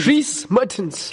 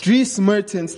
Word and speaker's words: Dries Mertens: Dries Mertens. – Dries Mertens, thank you.